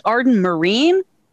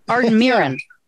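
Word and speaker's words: Arden Myrin: And wait, isn't it aren't we supposed to pronounce it Arden Marine? – Yeah, like what the good, the few, the brave, the Marines? Arden Mirren. – Mirren. – Like Helen Mirren.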